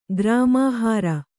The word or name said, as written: ಗ್ರಾಮಾಹಾರ